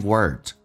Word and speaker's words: word: One kind word， word 的 d 也不发音， word 重度卷舌， word 整个舌身往回卷。